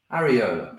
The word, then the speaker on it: areola: Now, and then I say this word slowly because I kept messing it up earlier when you taught me how to say it. Areola. – That's the one.